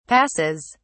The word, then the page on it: passes: British English